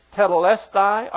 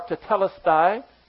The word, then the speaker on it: tetelestai: The fact that Jesus was still physically alive when He said, "It," referring to salvation, "is finished," which in the Greek, one word, tetelestai, or tetelestai, is pronounced both ways.